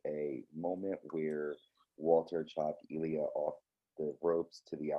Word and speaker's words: Ilya: I was thinking it was Eli, but it's Ilya.